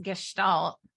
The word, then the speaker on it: Gestalt: And you're going to learn that it's pronounced Gestalt.